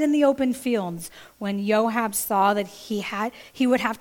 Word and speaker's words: Joab: I did look that one up because I was like, is it Job? Is it Joab? It is pronounced Joab, and he's a big shot in David's army.